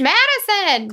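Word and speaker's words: Madyson: I was like, it's Madison. Close, close, close, close. So Madison had a dog, Maisie, and she loved this dog.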